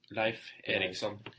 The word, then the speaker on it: Leif Erikson: Leif Erikson.